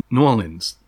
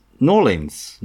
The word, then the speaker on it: New Orleans: The one that I always mispronounce is New Orleans, which apparently you should only ever call Norleans. – What? New Orleans? – Norleans. Not even New Orleans. Norleans.